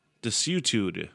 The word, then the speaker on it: desuetude: D-E-S-U-E-T-U. D-E. Desuetude? Desuetude. Desitude. Desitude. That's what I'm going with. Now I'm looking at the pronunciation. Nope, wrong. Desuetude.